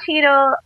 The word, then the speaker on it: Cheadle: so Don Cheadle,